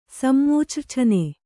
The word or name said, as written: ಸಮ್ಮೂಚ್ರ್ಛನೆ